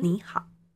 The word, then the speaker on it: 你好: 可是我在写的时候，我还是一样写好好，两个都是要打勾的，因为平常大家好，这个好就是一个三声字，嗯哼，你好，好也是三声字，哎、欸，可是你就从二声，它是一个三声字，好，它这时候就会念成二声。